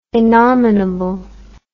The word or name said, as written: innominable